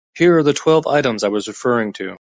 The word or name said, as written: referring